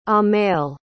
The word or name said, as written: amell